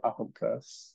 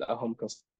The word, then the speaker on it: Ahomka: So Michael Ahomka, which is Michael spelled with A-E-L, and Ahomka spelled A-H-O-M-K-A, and then underscore.